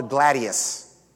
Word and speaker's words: gladius: Remember, it's called the gladius. Sounds like the word gladiator, right?